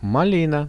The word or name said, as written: malina